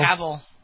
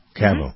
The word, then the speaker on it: Cavill: Cavill? – Cavill. – Cavill.